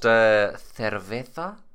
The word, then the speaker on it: cerveza: I'm drinking an imported Pacifico Clara, a Mexican Civetta. – Pacifico, yeah. – I believe it's pronounced cerveza, but whatever.